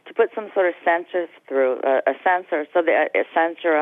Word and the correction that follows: censure: I'm not pronouncing it correctly, uh, so that there's something historically noting what we're going through. – Oh, censure.